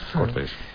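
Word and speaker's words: Cortes: Cortés. – Con el acento en la O, y la verdad que sí, son muchísimas leyendas asociadas a esta Virgen manchega, ¿no?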